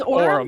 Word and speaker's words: Aurum: I believe I'm pronouncing that right. Aurum Store. A-U-R-U-M. Aurum. Aurum.